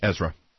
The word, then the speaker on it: Ezra: Ezra. – Urza. – Ezra. – Oh, okay, that's correct. I didn't say it right.